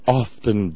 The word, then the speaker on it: often: I have often dined at yada, yada, yada. Often I have shopped at Saks Fifth Avenue, Bergdorf Goodman, whatever. Often. No. Look it up. Often. You don't pronounce the T. And it's not realtor. That would be R-E-L-A-T-O-R, realtor.